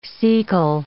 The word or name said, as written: cecal